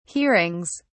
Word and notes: hearings: British English

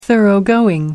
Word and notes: thoroughgoing: American English

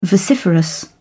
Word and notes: vociferous: British English

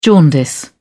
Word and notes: jaundice: British English

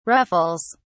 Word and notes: ruffles: British English

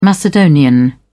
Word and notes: macedonian: British English

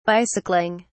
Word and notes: bicycling: British English

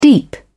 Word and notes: deep: British English

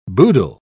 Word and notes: boodle: British English

